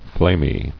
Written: Flamy

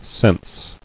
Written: Cense

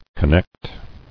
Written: Connector

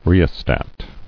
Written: Rheostat

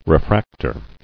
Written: Refractor

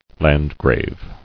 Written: Landgrave

